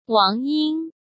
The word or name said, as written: Wang Ying